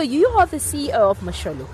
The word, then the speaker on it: Mosholu: Is that how you pronounce it? – Correct. It's Mosholu.